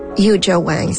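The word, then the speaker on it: Yuja Wang: Et puis, Yu Jia Wang, c'est comme ça qu'on prononce son nom.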